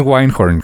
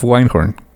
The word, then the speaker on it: Wyndhorn: Yo digo que es Winehorn. Helen of Winehorn. – Pues sí, normalmente esa es la pronunciación cuando en inglés hay una Y de por entre consonantes.